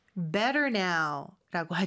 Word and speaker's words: better: Much better now라고 하죠. Better의 T 발음은 모음 소리 사이에 껴서 리을처럼 부드럽게 발음된다는 거.